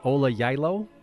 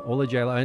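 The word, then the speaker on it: Ola Gjeilo: I'm probably going to slaughter the name of the composer here, but is it Ola Jailo? – You've nailed it. – Really? – Close to Jailo, yes. – Okay. So we need a soft J at the beginning.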